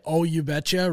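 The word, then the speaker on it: Ohh You Betcha: So you say, oh, you betcha, right? But there's an extra H in there, a silent H.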